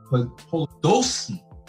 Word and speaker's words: Podolsky: Today we have Mark Podolsky.